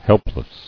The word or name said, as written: Helpless